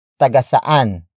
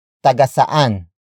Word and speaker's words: tagasaan: Word for word, it's tagasaan sila. Tagasaan sila.